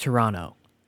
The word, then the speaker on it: Toronto: Yeah, it's pronounced Toronto. No T. No second T. – Yeah. Silent. – Silent T.